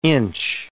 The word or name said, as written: inch